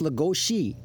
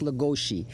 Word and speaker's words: Lugosi: All right, we heard Bela Lugosi is, is, is pronounced Lugosi. It is actually pronounced Lagoshi.